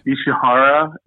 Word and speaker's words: Ishihara: It is Isahara. I probably said it wrong. Isahara, uh, Japan. – Ishihara. – Ishihara. Ishihara. Yeah. – Ishihara, I think is how it's pronounced.